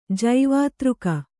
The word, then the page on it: ಜೈವಾತೃಕ: kannada